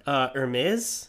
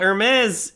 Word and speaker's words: Hermès: Hermès. – Oh, okay. – Okay, so that's... – you went like... Eh. – yeah. Well, Hermès is spelled by Hermes. – Yeah, Hermes.